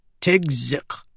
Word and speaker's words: Tigzik: That kuk sound on the end of his name sounded like choking when someone pronounced it correctly. She just called him Tig instead. – "Tigzik," Hukin said.